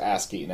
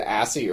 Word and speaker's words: ASCII: I think it's actually pronounced ASCII, now that I'm saying that. I said ASCII earlier. – ASCII. – And now I'm realizing that was stupid. I think it's ASCII. ASCII Corporation.